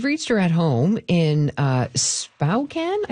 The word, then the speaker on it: Spokane: It is Spokane, okay.